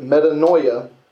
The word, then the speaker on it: metanoia: If you look at the Greek, the word metanoia is the word used for repentance, and it's spelled M-E-T-A-N-O-I-A.